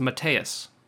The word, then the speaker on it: Mateusz: Mateusz Gamrot. He says his name is pronounced Mateus, and it's actually very easy to pronounce if you look at it. You just don't pronounce the Z, and it's Mateus.